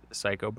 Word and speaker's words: Cayko: It is pronounced Cayko, but it is spelled CAYKO. That's CAYKO.